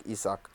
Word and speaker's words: Isak: I suck, you suck, we all suck, Isak.